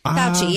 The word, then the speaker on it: Fauci: Steph Curry talking to Dr. Fauci. Am I saying that right, do you think? – Fauci, yeah.